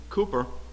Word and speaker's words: Cowper: And so this one from William Cooper, you know, it's spelled C-O-W-P-E-R, but it's pronounced Cooper.